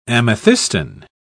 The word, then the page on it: amethystine: British English